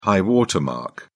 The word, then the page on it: high water mark: British English